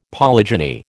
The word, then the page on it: polygeny: American English